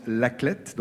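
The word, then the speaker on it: La Clayette: L'Aclette, ça s'écrit Cléette, mais on prononce L'Aclette, donc.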